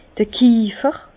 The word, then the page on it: d'r Kiefer: Bas-Rhin; Herrlisheim